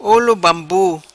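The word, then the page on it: Olo Bambu: patua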